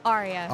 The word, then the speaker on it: Aria: Aria.